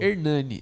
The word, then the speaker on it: Ernani: É, o meu Instagram é iai.hernani, arroba é E-R-N-A-N-I. Meu nome é meio complicado, né? – É, é, com H ou sem H, o Hernani? Sem H. Sem é H, hein, galera? E-R-N-A-N-I. Exatamente como se fala, mas existe tantas variações que as pessoas ficam confusas.